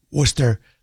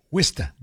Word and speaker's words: Worcester: He doesn't say Worcester. He says Wista, W-I-S-T-A. Wow. I think that is the actual real New England pronunciation of it.